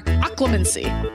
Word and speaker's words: Occlumency: Occlumency. Did I pronounce that right? – Yeah, occlumency.